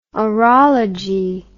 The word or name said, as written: orology